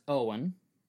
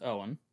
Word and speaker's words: Eoin: E-O-I-N. – Mm-hmm. – That's the first name. – Okay, that is Owen. That's pronounced Owen. – O apostrophe F-A-O-D-H-A-G-A-I-N. – Go back against F-A what? – F-A-O-D-H-A-G-A-I-N. – F-A-O-D-H...